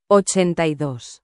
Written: 82

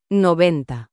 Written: 90

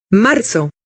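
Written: marzo

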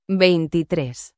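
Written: veintitrés

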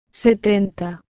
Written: 70

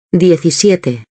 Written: 17

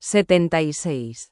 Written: 76

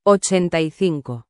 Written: 85

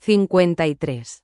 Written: 53

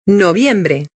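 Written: noviembre

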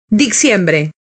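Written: diciembre

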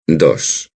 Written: dos

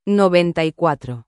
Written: 94

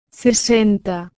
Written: 60

